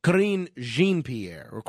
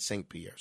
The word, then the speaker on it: Karine Jean-Pierre: Karine Jean-Pierre, I believe is the way it's pronounced. I said Saint Pierre.